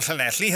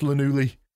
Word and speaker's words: Llanelli: Well, I grabbed hold of my crown jewels quicker than you can say Llewellyn the librarian from Llanelli has licked my toads. – Actually, I think you'll find it's pronounced Llanelli.